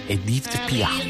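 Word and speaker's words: Édith Piaf: Edith Pia.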